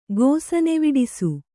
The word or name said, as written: ಗೋಸನೆವಿಡಿಸು